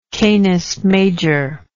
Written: canis major

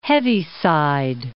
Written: Heaviside

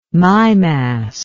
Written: mimas

